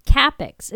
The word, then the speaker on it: Cappex: So, again, to recap, CAPPEX is spelled C A P P E X dot com.